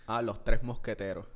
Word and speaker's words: Mosqueteros: How do you say Musketeer? – Los Tres Mosqueteros.